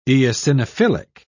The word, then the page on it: eosinophilic: British English